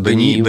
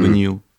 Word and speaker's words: Даниил: Так, как, как у тебя имя правильно произносится? – Даниил. Данил, Не Данил. Mm-hmm. – Даниил. – Все, я запомнил.